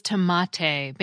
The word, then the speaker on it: tomate: In Spanish, the name for these little beauties was tomate, based on the sound system of Spanish, which, like most Romance languages, used the long A vowel, pronounced ah, which was the closest vowel sound to the one heard in the original Nahuatl word.